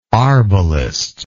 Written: arbalister

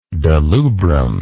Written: delubrum

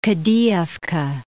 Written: Kadiyevka

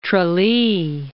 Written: Tralee

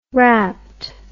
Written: wrapped

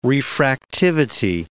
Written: refractivity

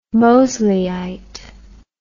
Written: Mosleyite

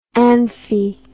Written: amphi

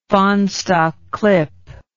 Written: fahnestock clip

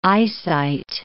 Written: eyesight

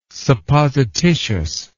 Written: supposititious

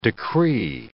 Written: decree

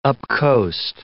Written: upcoast

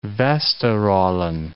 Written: Vesteralen

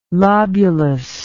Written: lobulus

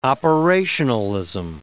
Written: operationalism

